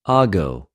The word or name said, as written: ἀγω